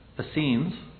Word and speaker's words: Essenes: Essenes.